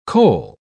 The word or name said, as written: coal